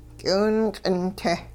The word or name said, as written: k'iwingq'in-te